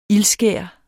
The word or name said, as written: ildskær